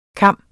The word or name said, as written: cam